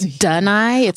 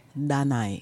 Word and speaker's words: Danai: My mom just couldn't bear because it's not Danai. It's Da. Like it's a D that's only in my language. So it's very tricky to say if you're not Shauna.